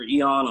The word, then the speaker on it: Aeon: I'm Trevor Eon on everything. The Trevor is spelled T-R-E-V-E-R, and Eon is spelled A-E-O-N because I stole it from Eon Flux.